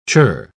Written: churr

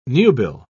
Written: nubile

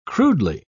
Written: crudely

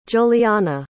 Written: giuliana

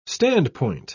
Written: standpoint

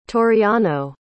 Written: toriano

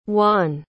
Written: wann

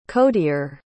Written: codier

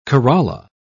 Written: corolla

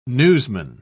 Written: newsman

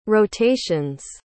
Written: rotations

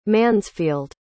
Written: Mansfield

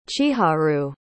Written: chiharu

